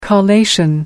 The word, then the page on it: collation: American English